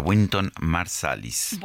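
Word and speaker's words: Wynton Marsalis: Y sí que la verdad es que no estaba en nuestra lista, o sea que por eso no, no voté por Winton, así se pronuncia, Winton Marsalis.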